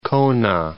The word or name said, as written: Kona